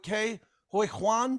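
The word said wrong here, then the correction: Ke Huy Quan: Did I get the name right, please? – Yeah, you're close. Kihi Kwan. – Kihi Kwan, okay. I was not close.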